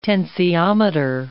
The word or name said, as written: tensiometer